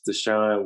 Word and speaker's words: Deshaun: And you can find me on Instagram at It's Deshaun Williams. So Deshaun is spelled D-E-S-H-A-U-N.